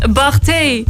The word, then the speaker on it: Barthez: Fabienne Barthez, Frans voetballer. – Ja, als er een S staat, spreek je niet uit. Als je met een Z staat, spreek je wel uit.